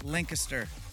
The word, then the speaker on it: Lancaster: It's pronounced Lancaster.